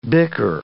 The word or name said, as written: bicker